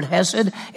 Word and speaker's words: hesed: That's that Hebrew word hesed, H-E-S-E-D. Hesed is how you pronounce it.